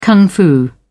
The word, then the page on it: kung fu: British English